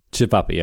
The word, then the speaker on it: Cevapi: Cevapi.